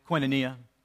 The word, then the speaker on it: koinonia: Koinonia is the English transliteration of the Greek word, which is pronounced, by the way, koinonia, hence a transliteration.